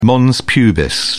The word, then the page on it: mons pubis: British English